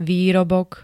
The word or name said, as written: výrobok